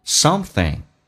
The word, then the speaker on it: something: Something 记住咬舌头舌尖往上指搭一下然后呢是一个后鼻音整个舌身往回缩一点点叫嗯 Something